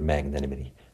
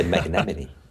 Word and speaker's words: magnanimity: They say magnanimity. – I almost said it myself, so, actually. – I was impressed. I was impressed to see that you pronounced it very well, magnanimity. I think the problem with Americans is they have a problem with the nanny, you know, magnanny. So I tell them, look, just say magna, and then after nimity, and it will work well.